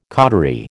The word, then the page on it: cautery: American English